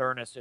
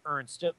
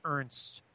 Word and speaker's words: Ernst: It's interesting that he's actually, it's not Earnest, it's Ernst. Josh Ernst. It looks like Earnest phonetically, but it's actually Ernst.